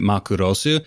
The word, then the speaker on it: Macross: And don't at me saying it's pronounced Macross, because we both know it's really Makurosu, and I can anglicize it however I want.